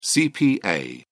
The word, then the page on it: cpa: British English